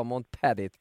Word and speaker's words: Montparis: Har du sett uttalen fint nu? Det där var ett uttal vi jobbade med hela säsongen ju. Han sa själv att det skulle vara Montparis, precis.